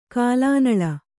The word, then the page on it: ಕಲುನೆಲ: Kannada